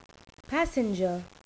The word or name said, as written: passenger